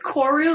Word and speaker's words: Koru: It's Koru, like a like a the Earth's core kind of oh, thing. Oh, core. Okay, yes.